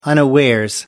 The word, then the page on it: unawares: American English